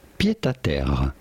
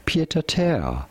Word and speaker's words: pied-à-terre: In French, we would say pied-à-terre. A pied pied-à-terre. But it'd be fine in English to call it pied-à-terre.